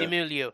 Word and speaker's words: Simu Liu: Is it Simu Liu? – Yeah. – Simu Liu, yeah.